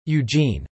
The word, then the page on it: eugene: American English